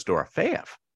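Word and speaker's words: Dorofeyev: Well, I just go back to the common you take the second syllable of the name and you go Dorofiev.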